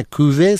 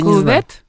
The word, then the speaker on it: Кюве: «Кюве Санкт-Петербург». – «Кюве», да. – «Кюве», как пишется, «кювет»? – Нет, «Кюве». «Кюве». «Кюве».